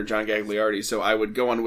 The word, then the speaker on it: John Gagliardi: The all-time winningest coach, John Gagliardi.